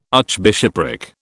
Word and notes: archbishopric: British English

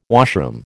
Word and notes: washroom: American English

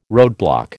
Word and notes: roadblock: American English

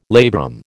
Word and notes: labrum: American English

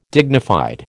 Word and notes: dignified: American English

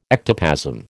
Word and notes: ectoplasm: American English

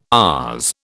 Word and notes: ours: British English

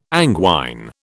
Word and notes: anguine: British English

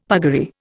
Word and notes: puggaree: Indian

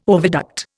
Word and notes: oviduct: Indian

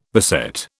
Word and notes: beset: British English

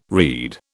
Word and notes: read: British English

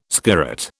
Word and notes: skirret: British English

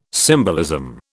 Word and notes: symbolism: British English

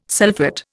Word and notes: sulphate: Indian

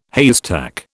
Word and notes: haystack: British English

